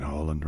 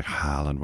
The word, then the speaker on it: Haaland: I need to start saying Haaland, right? Because that's his name. You know, I've got to stop mispronouncing it like Haaland.